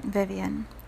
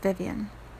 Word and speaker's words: Vivian: So for example, if you say my name, Vivian, in English, it's just Vivian. If you pronounce it in the Spanish interpretation, which means they live, it is viven, right?